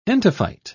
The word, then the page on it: entophyte: British English